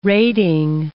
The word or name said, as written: rating